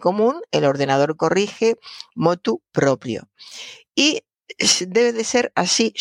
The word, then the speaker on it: proprio: Eh, fíjese, motu propio se escribe como lo estoy pronunciando, propio, con R en las dos sílabas, propio.